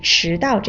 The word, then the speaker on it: 迟到: 不过啊，叶卡，我刚才听到你的一个发音问题，就是“ 迟到” 这个词，迟到，迟是二声。